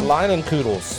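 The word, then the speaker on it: Leinenkugel's: Leinenkugels. – Leinenkugels. – Leinenkugels.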